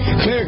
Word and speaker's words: Xlear: It's pronounced clear, but it's spelled X-L-E-A-R.